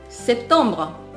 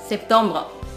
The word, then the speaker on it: septembre: セプトンブロセプトンブロ発音のポイントは真ん中のトンにアクセントを置いて最後のブこれはスッと抜きます